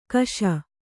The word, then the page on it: ಕೇಶ: kēśa